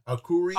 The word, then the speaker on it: Arcuri: Boris Johnson confirms Jennifer Arcuri. How do I say this name? Arcuri? – Arcuri, yeah.